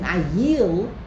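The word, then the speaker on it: ayil (אַיִל): and the only difference between the two is the pronunciation. An ayol is a chaya, a wild animal. An ayil is a ram. It's a domesticated animal.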